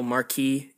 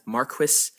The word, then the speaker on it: Marquis: Incidentally, I am aware that the title Marquis is pronounced in the UK as Marquis, but since I took French in high school, I prefer the French pronunciation, so that's what I'll be going with.